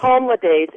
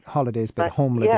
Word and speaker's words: HomeLidays: Home Le Days. It's spelled HomeLidays. – Okay, like holidays, but Home Le Days. – Yes, but Home Le Days.